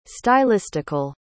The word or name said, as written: stylistical